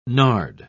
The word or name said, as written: nard